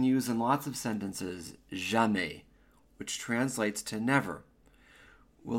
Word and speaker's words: jamais: Je ne comprends jamais ces leçons. I never understand these lessons. Let's begin. Pronunciation. The pronunciation of never in French is jamais. Jamais.